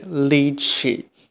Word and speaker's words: litchi: 那这里的 l i 发音是 li，c h i 的发音是 che，合在一起就是 lychee。那中间有个 t，那这里的 t 是不发音的。